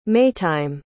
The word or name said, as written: maytime